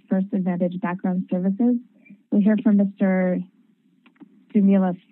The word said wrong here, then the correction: Sumulus: first. Is that how, am I pronouncing that correctly? – Good morning, Judge Grant. I pronounce it Sumulus. – Sumulus, thank you.